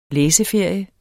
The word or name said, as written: læseferie